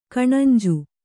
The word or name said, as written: ಕಣಂಜು